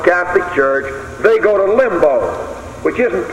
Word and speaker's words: Limbo: The fourth place is called Limbo, L-I-M-B-U-S, and it's often pronounced Limbo, L-I-M-B-O.